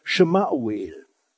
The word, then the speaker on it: Samuel: Samuel müssten wir eigentlich richtig aussprechen, Shemauel. Und Shema heißt Hören auf Hebräisch, also aus das hebräische Shema heißt Hören. Und Shemauel, das bedeutet übersetzt, Gott hört.